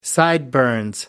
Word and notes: sideburns: American English

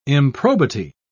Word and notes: improbity: British English

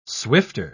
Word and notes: swifter: British English

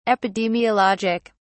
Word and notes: epidemiologic: British English

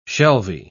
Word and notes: shelvy: British English

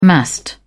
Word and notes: massed: British English

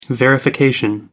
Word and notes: verification: British English

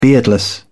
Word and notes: beardless: British English

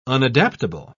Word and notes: unadaptable: British English

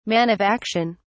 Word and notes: man of action: British English

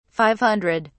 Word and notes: five hundred: British English

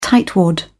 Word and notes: tightwad: British English

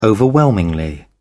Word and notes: overwhelmingly: British English

